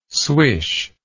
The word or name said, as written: swish